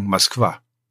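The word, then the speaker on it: Moskwa (Москва): Aber es wird ganz anders ausgesprochen. Es wird hinten betont und es wird dann ausgesprochen Moskwa. Du hast vorne kein O, sondern ein A. Und dieser Wandel, wir nennen das Akane, also ein unbetontes O wird wie A ausgesprochen.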